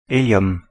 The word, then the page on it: hélium: Paris; /e.ljɔm/